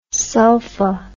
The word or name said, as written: sulpha